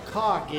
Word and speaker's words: chag: How many of you know what a cog is? Cog. It's spelled C-H-A-G, but it's pronounced K-H-A-G.